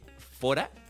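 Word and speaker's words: Fårö: la que vivió Bergman en la isla de Fora, que se escribe Faro.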